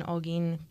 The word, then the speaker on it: Holguín: He was born on October 10th in 1954 in Holguín, Cuba. I looked up how to pronounce it, and that's what it said, so I'm just going off that.